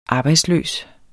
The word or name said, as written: arbejdsløs